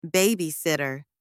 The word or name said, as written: babysitter